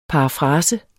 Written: parafrase

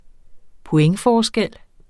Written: pointforskel

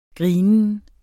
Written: grinen

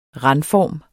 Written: randform

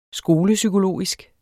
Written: skolepsykologisk

